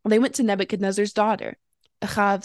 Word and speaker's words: Ahav: And this Talmudic story takes place in the land of Babylon under the reign of Nebuchadnezzar and refers to two false prophets, Ahav and Zedekiah, which are the Hebrew pronunciations of Ahab and Zedekiah, if you've heard those names before, but no relation to anyone in the Bible.